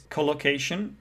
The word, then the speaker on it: collocation: I think it should be pronounced collocation. Yeah, so there are two schwa sounds instead of collocation. It's collocation. Yeah, I agree with you.